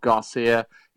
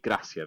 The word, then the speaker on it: Gracia: So it's not misspelled, it's not Garcia. He's not linked with Masters champion Sergio Garcia in any way, or bloodline, or brother, or anything like that. It is Javi Gracia. That's how you say his name.